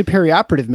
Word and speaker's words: perioperative: It's actually perioperative medicine, so anyway, that's how you pronounce that.